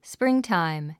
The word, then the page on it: springtime: spríŋtàim; スプリンタァイム